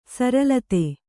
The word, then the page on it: ಸರಲತೆ: saralate